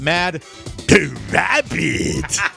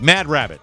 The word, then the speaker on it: Mad Rabbit: Now is the time to try out Mad Rabbit. Sorry, I'll say it right. Mad Rabbit.